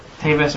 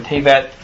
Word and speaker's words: Tevet: Tevet or Tevet, again, depending on your Ashkenazi versus non-Ashkenazi pronunciation.